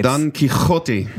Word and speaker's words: Don Quixote: which is the musical version of Don Quixote, which is where the phrase "they might be giants" originates. – Don Quixote. – Yeah, that's the thank you. – The correct pronunciation.